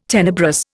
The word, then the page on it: tenebrous: Indian